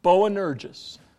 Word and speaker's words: Boanerges: Okay, how do you pronounce that? It's actually three syllables. Boanerges. Boanerges.